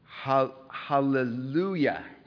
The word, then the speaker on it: Hallelujah: Hallelujah.